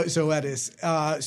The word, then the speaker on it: Zoetis: Let's do Zoetis. Because this stock is a mover. – Is that how you say it? I didn't know. – Yeah, Zoetis.